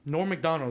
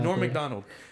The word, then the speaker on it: Norm MacDonald: Norm MacDonald is what we meant to say. – Yes. – We were saying it the wrong time. I think both of us said it wrong the whole time.